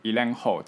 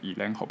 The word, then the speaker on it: Gyllenhaal: 那他那个名字好像很多人都会念错，他叫做 Jack Gyllenhaal Gyllenhaal 这个是瑞典的发音，要念 Jack Gyllenhaal 不是 Gyllenhaal 对，